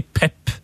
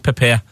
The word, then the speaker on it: Pep: men hvordan føles det da når, når du liksom eh, velger å si Pep istedenfor PP, som alle sier? altså har du egentlig lyst til å si PP? Nei. Nei? Du har lyst til å si pep?